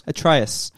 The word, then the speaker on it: Atreus: Atreus.